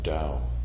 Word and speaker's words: Tao: T-A-O is pronounced Dao.